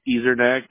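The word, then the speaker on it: Ezernack: Ezernak, that's E-Z-E. R-N-A-C-K.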